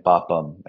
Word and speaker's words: papam: So we're South Indian, so we in our sort of dialect, we'd say punyam and papam.